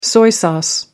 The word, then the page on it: soy sauce: American English